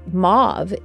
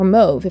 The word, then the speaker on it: mauve: Interestingly, I think it's very interesting, Archangel Sandalphon is associated with, well, many colors like turquoise, but mauve is one of the colors that Archangel Sandalphon is associated with, or mauve if you prefer the more French pronunciation.